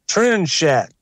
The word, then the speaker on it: Trinxat: But they have a dish that's spelled T-R-I-N-X-A-T, and it's pronounced Trinchat.